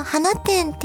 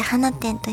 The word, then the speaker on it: 放出: そう、花店っていうのはこれ放出と書いて花店と読むんです。